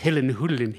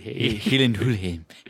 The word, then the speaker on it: Gyllenhaal: But it does if you know it's pronounced Hillen Hullen. – Hillen Hullen.